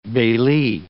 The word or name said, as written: bailee